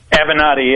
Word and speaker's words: Avenatti: Listen, I got to ask you, how do you say this guy's name? Is it Avenatti? Am I saying it right? – Avenatti, yeah.